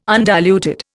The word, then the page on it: undiluted: Indian